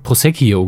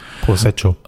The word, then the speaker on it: Prosecco: Sie hat Pros Prosecchio gesagt. Sie hat irgendwie noch eine Silbe da reingebatscht. Prosecco.